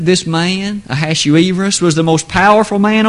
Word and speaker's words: Ahasuerus: Some pronounce his name Ahasuerus. I'll attempt to stick to Ahasuerus.